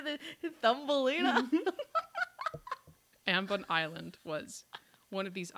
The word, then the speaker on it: Ambon: The story that I'm going to tell you takes place on an island in Indonesia called Ambon. I've seen it pronounced Ambon, Ambon, Ambon, but. Abon. Abon. A M B O N. I'm going to say Ambon.